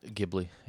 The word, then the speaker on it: Ghibli: Is that right? – Ghibli, yeah. – Ghibli is what we discovered thanks to Wikipedia.